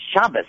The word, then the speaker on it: Shabbat: Uh, this program I really started out as Turn Friday Night into Shabbos. – Right. – Okay, with the Ashkenazic pronunciation. – Right. And eventually we felt that the people that we are reaching out to are the ones who would probably call it Shabbat so the name was changed.